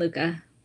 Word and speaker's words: Lucca: Jacqueline Lucca.